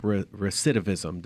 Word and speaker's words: recidivism: To stop recidivism. Did I say that correctly? – That's it.